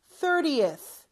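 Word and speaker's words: thirtieth: 이거는 발음을 수월하게 해주기 위해서 으가 중간에 붙습니다. 30th.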